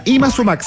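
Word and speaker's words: Yma Sumac: Ima Sumac, se escribe Y-M-A Sumac.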